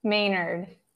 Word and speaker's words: Maynard: Maynard.